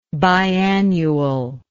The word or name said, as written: biannually